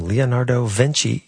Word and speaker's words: Leonardo Vinci: Here's something from Leonardo Vinci.